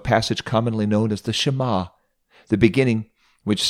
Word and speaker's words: Shema: Let me teach you how to say the Shema in Hebrew. Shema, Israel, Adonai Eloheinu Adonai Echad.